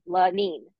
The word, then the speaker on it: Lenyne: And she even wrote it out phonetically because if you say her name phonetically, it's just LaNeeN, Lanine, but Yes. – it's spelled LENYNE.